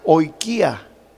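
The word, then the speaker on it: oikía (οἰκία): La palabra casa, aquí en el Nuevo Testamento, viene de una palabra griega que se pronuncia oikía.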